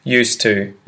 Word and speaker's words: used to: it just merges and becomes used to, used to, used to, used to. They're used to. So that's a little like a native insert there on how to pronounce that. I'm used to, you're used to. It's just used to. One consonant in the middle there.